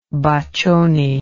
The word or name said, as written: Boccioni